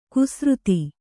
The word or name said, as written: ಕುಸೃತಿ